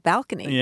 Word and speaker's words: balcony: And you've provided me the opportunity to mention that that was the original pronunciation in English of balcony. – Yeah, I use balcony.